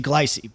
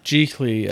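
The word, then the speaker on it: Gigli: It's not intuitive at all because I look at it and I see Glycy, but that's not. – It's not. It's G I C L E E. – Yeah. – Giggly, as I believe how it's pronounced.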